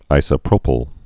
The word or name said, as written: isopropyl alcohol